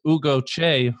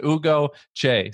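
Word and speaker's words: Ugo Cei: So the big challenge when we're doing the podcast is when I say that your name is Ugo Che, who knows how to spell that?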